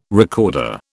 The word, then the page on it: recorder: British English